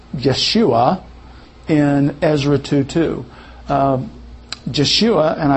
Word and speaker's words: Yeshua: They'll say Yeshua. Now, because of German influence, the Y became a J.